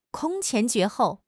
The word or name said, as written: 空前绝后